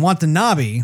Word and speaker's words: Watanabe: But wantanabi um, Watanabe, did I say his name right? Mm-hmm. Yes.